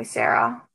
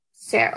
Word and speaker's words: Sara: When I'm in China, like, some of my family tries to call me Sarah, but our, like, some northern dialects, it's kind of hard to pronounce, like, the Sarah, like, it just, like, the R turns into an L, and it's, like, but most of my family tries to call me Sarah.